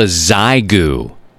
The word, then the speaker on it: Xiegu: It's called a Zygu. It's spelled X-I-E-G-U.